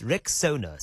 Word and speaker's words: Rexona: A brand you've trusted for years is stepping forward with a bold new name, and it's pronounced Rexona.